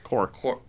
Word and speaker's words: corps: How would you say C-O-R-P-S? – Corps. – You would just leave the P silent.